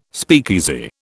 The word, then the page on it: speakeasy: British English